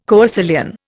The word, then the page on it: coecilian: Indian